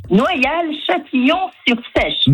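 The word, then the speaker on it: Noyal-Châtillon-sur-Seiche: Noyal-Châtillon-sur-Sèche.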